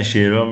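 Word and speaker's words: Shearer: خب شیرر فکر کنم، نه درسته؟ – شیرر میگن، شیرا میگن، شیرا بیشتر میگن تا شیرر. ولی خب شیرر نوشته میشه، شیرا میگن. الان شیرا اسم سختی داره.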